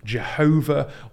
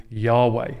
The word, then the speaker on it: Yahweh: It has been pronounced Jehovah, or perhaps more accurately, Yahweh.